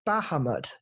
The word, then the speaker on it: Bahamut: Bahamut, yes, I was close enough.